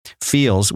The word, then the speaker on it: Feals: Feels, which is spelled F-E-A-L-S, it is a premium CBD that is delivered directly to your doorstep.